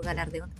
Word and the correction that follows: galardón: ¿cómo se dice? – Galardón.